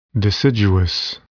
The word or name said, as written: deciduous